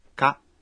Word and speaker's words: か: Ka. – Es una partícula interrogativa que se pronuncia en tono ascendente.